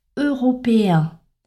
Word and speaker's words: européen: Autre mot qui pose souvent problème, l'adjectif européen. Européen, donc c'est la graphie E-E-N. Pour bien prononcer cet adjectif, il faut enchaîner la voyelle E avec la voyelle nasale 1. Et pour y arriver, en fait, il faut baisser l'intonation entre le E et le 1.